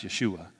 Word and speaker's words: Yeshua: He heard the name Joshua. That's pronounced Yeshua.